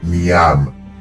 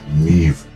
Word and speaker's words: Niamh: Niv. Se escribe Niam, N-I-A-M-H, Niam, pero se pronuncia Niv este protagonista,